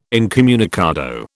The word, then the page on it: incommunicado: British English